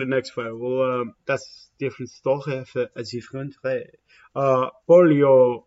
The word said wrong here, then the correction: Paulo: or Polio, I said that wrong, you kept saying it kind of things. But Paulo Costa versus Rock or Lockhold.